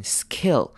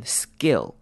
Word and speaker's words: skill: 好，skill 这个字要注意的是，sk 开头的字呢，我们会把 k 念成 g，像是 g 的声音，所以这个不会念成 skill，而是念成 skill。有没有发现是 g 的声音？skill。